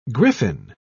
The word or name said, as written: griffin